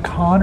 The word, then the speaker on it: Cannes: Is that con or can? – Con.